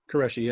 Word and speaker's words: Qureshi: Qureshi? – Qureshi, yeah.